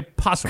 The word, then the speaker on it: opossum: Do you always say an opossum when you see one? No, you say possum. – Because the O is silent.